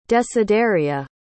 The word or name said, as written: desideria